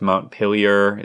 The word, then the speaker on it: Montpelier: Montpelier, is that how you say it? – Vermont. Yeah.